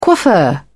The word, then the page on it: coiffeur: British English